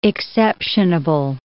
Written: exceptionable

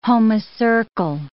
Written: homocercal

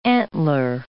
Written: antler

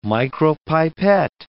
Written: micropipette

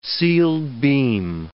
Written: sealed-beam

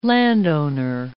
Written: landowner

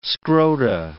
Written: scrota

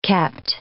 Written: kept

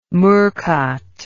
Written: murcott

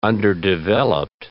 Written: underdeveloped

